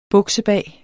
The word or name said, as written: buksebag